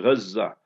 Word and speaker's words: Gaza: You know how we pronounce it, Gaza.